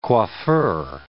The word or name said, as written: coiffeur